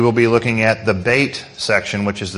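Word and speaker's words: Bet (בית): Some of you may have it spelled B-E-T-H at the top, but that's actually not the way you pronounce it. That is, well, okay. There's two ways of pronouncing Hebrew. There's the classical, which would be Bet, but modern Hebrew actually pronounces this letter Bet.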